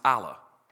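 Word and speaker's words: Allah (ἀλλά): It's pronounced Allah. It's how you say but, not like your rear end, like you know, instead of B-U-T, not two T's.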